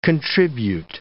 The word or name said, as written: contribute